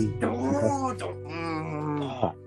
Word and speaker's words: Danganronpa: It's like Danganronpa. – Yes. – Danganronpa.